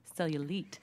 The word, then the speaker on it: cellulite: You know, again, you might get your circulation going, so cellulite would be, or as we learned, it might be called cellulite. Might not. – Yes, that's an alternative pronunciation.